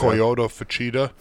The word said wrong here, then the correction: Koya Fujita: Fuchida. Coyo. Koya Fujita. Fujita.